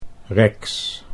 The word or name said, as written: Rex